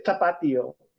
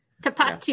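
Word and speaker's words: Tapatío: Saying it phonetically like a Minnesotan. – There's an accent, right? It's Tapatio. – Yeah. – Right? Is that right? Which is it? – I would say Tapatio.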